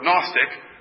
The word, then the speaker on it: Gnostic: It's the basic root word for Gnostic, which is spelled gnostic, has the G in front. But in English, we don't pronounce the first letter.